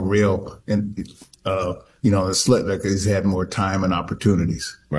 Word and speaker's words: Schlittler: I've seen probably Skeens pitch twice on TV, and I've seen, how you say his name, Schlittler? – Yeah, Schlittler. Yeah, the top I've seen him pitch once early in the year.